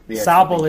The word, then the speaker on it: Sobble: It's literally the holo versions of Sword and Shield, of Grokey, Snobble. No, I said it wrong. – Snobble. – Snubble? – Snobble. Snobble. – It's Snobble. – There's a Snubble and there's a Sobble. – Yeah. Sobble? – It's Sobble.